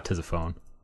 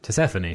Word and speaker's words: Tisiphone: How do you pronounce it? – Tisephone. – Tisephone. – Like Persephone. Yeah. It's not Persephone. Spelled the same.